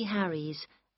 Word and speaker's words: Harries: J-E-N-N-Y-C-H-A-N. – Number seven. – Wendy Harries. That's H-A-R-R-I-E-S.